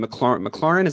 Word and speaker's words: McLaurin: They've got a wide receiver who I have in my fantasy team, McLaurin.